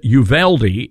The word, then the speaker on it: Uvalde: We understand law enforcement is on site, and is Uvalde is the correct pronunciation.